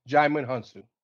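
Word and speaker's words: Djimon Hounsou: And I know I'm going to mispronounce his name. Jimon Hunsa? – Jimon Hunsa.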